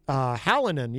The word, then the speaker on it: Hallinan: Charlie, I got his name right. Hallinan. Yes. He said I said it correctly on the first try, which is wonderful.